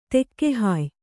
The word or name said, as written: ತೆಕ್ಕೆಹಾಯ್